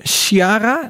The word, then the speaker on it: Ciara: Dat komt door Storm Ciara. Zeg ik het goed, jongens? Volgens mij wel. – Ik moet moet ehm, aan een Sierra denken.